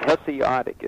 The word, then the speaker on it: Hesiodic: The Hesiodic is how you say it. – Hesiodic pantheon was evil enough.